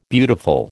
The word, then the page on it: beautiful: American English